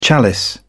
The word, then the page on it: chalice: British English